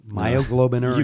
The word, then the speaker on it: myoglobinuria: Myoglobinurinaria. – No, myoglobinuria. – You added two syllables.